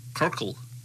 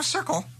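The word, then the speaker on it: Cyrkle: What's that? Kirkle. – No, Circle. – Kirkle. – No, it's still a soft C.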